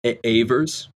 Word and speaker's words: Ewers: Ewers? Like that? – Yes, yes. It was actually the first girl I ever dated. That is the correct pronunciation.